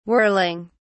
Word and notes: whirling: British English

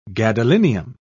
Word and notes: gadolinium: British English

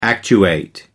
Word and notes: actuate: American English

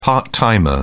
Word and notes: part-timer: British English

